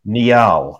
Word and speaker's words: Niall: And he ruled in favor of my pronunciation, Neil.